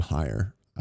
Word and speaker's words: Hier: This is from Justin, I believe you pronounce his last name higher. H-I-E-R.